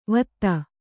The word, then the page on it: ವತ್ತ: kannada